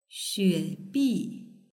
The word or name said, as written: xuebi